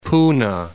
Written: Poona

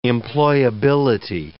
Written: employability

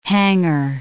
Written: hangar

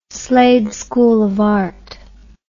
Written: Slade School of Art

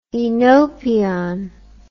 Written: Oenopion

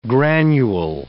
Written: granule

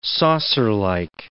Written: saucerlike

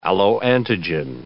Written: alloantigen

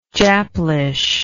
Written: japlish

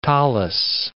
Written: tallis

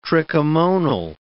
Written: trichomonal

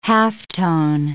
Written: halftone

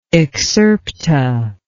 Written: excerpta

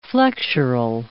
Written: flexural

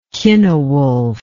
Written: cynewulf

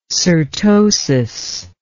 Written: cyrtosis

